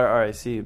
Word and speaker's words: RAC: it's it's what I, it's actually either. I, I normally call it RAC, but uh, well, a lot of people call it rack. I don't, I don't really care actually.